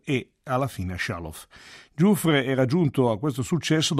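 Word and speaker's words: Giuffrè: Nato a Dallas nel 1921 da una famiglia di origine siciliana, quindi il cognome in realtà dovrebbe essere Giuffre con l'accento, a 9 anni aveva iniziato lo studio del clarinetto, di cui ha sempre privilegiato il registro grave.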